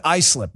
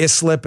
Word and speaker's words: Islip: It's supposed to be flying up to Islip, not Islip, as I heard a few people say this morning on TV. It's called Islip, not Islip. It's a common mispronunciation, not a big deal.